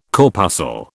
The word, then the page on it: corpuscle: British English